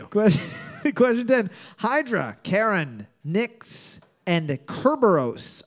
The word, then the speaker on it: Charon: I prefer Charon rather than Charon. – Yeah, it's Charon. Definitely Charon. It's no, the H is silent. – Charon. – It's Charon. K Carol.